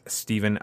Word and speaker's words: Stephen: My username is at Stephen underscore G.